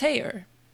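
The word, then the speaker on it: Haigha: His name is Heyer." – He pronounced it so as to rhyme with mayor.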